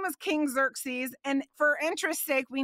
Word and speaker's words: Xerxes: It doesn't look like it's spelled like that, but if you look it up in the original language, that's the way that it's supposed to be sound, apparently. Xerxes, who reigned from India to Ethiopia over 127 provinces.